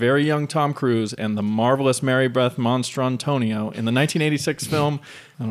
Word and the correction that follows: Mastrantonio: Because that's not how you say it. You don't say a monster. – Monstrantonio. – Monstrantonio. – I believe it's Mastrantonio. – Monster? – There's an N in it? M-A-S-T-R-A-N.